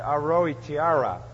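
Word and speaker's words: Aotearoa: In fact, when they discovered New Zealand, they called it Aroitara. I'll say it right, Aroitiara.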